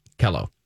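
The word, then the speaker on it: KELO: A college friend of mine did weather at Kilo in the morning. – It's Kello, honey. You're saying it wrong.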